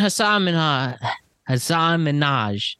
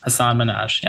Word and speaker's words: Hasan Minhaj: Comedian Hasan Minhaj. That's his name. – Yeah, Hasan Minhaj, yeah. – I will stop with the mispronunciations today.